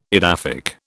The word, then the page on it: edaphic: British English